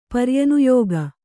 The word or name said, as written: ಪರ್ಯನುಯೋಗ